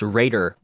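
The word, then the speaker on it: Reder: And why it's still pronounced Raider.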